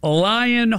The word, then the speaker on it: Lion: All right, James Biden, who received a subpoena to appear before a House impeachment inquiry, controls Lion Hall Group. You lying! You're lying, all right. – Mm-hmm. – You got the right name for that. No, it's spelled L-I-O-N, lion, like roar.